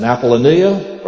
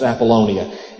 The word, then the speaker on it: Apollonia: In verse 1, there's the mention of Amphipolis and Apollonia, and then also Thessalonica. I think I said that wrong. It's Apollonia.